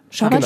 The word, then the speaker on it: Schabbat: Schabbat spricht man aus? – Schabbat. – Schabbat, auf der zweiten Silbe betont.